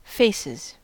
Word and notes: faces: American English

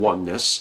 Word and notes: oneness: American English

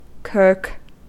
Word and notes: kirk: American English

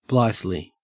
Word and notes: blithely: Australian English

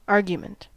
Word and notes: argument: American English

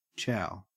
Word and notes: chow: Australian English

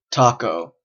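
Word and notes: taco: Canadian English

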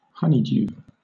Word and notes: honeydew: British English

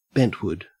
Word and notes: bentwood: Australian English

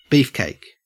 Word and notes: beefcake: Australian English

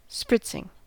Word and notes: spritzing: American English

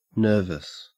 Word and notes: nervous: Australian English

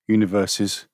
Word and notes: universes: British English

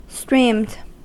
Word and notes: streamed: American English

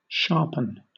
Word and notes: sharpen: British English

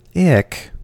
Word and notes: ick: American English

